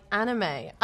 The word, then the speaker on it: anime: Well, this one is pronounced quite wrong by some English learners. It's because the word anime is not invented in English, right? I think so. Uh, maybe this is the reason why it's pronounced like how it is, right? Anime not anime or something else.